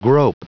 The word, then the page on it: grope: anglais